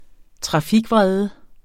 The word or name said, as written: trafikvrede